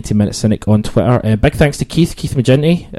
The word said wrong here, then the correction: McGinty: McGinty, shit, every single time I say his name wrong. Thanks to Keith Hard G.